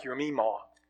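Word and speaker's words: your meemaw (ימימה): Jemima, in the original language, in the Hebrew, if you bring it over into English, it sounds like your meemaw. So you go from Jemima to your meemaw in the pronunciation of that.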